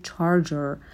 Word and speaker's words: charger: توی فارسی میگیم شارژر ولی توی انگلیسی میگیم charger.